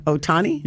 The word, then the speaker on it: Ohtani: Ohtani? – Yep, that's it.